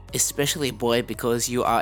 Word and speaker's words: especially: especially 的话，还有一个连音，那它有四个音节，里面的有一个 c，它的 c 我们所谓叫做 soft c，那这个 c 的发音是念 s 的音，所以变 especially。